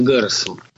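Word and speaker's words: Гаррос: Многие почему-то ставят ударение на второй слог его фамилии. Сам он всегда себя называл именно Гарресом.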